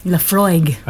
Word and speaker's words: Laphroaig: All right, so this is Laphroaig. – Laphroaig.